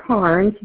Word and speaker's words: Carnes: I'm Cindy Carnes. The last name is spelled C-A-R-N as in Nancy E-S.